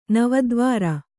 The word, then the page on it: ನವದ್ವಾರ: Kannada